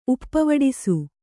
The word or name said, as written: ಉಪ್ಪವಡಿಸು